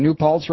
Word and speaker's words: New Paltz: Rocco is calling from New Paltz, New York. I hope I'm saying that right. Is it New Paltz, Rocco? – Yeah, you got that right, Mike.